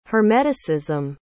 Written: hermeticism

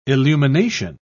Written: illumination

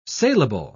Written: saleable

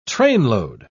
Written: trainload

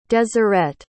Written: deseret

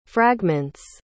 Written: fragments

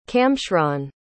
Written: camshron

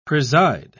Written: preside

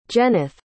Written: Jeneth

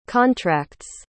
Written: contracts

